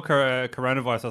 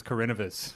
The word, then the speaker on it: coronavirus: I call coronavirus. I thought it was Carinivus.